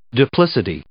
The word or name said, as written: duplicity